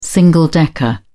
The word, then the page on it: single decker: British English